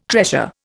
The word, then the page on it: treasure: Indian